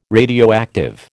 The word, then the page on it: radioactive: American English